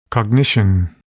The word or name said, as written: cognition